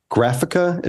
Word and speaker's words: Graphika: Graphica, is that how you pronounce it? – Yes.